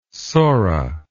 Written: sora

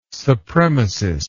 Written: supremacist

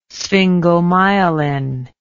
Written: sphingomyelin

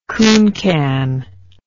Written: cooncan

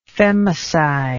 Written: femicide

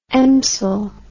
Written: emsel

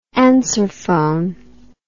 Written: answerphone

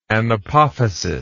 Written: anapophysis

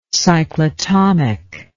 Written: cyclotomic

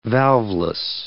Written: valveless

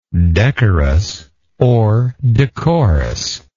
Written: decorousness

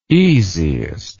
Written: easiest

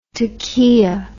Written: toquilla

